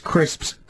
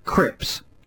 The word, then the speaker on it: crisps: We've got somebody who says that a relative pronounces crisps, crips.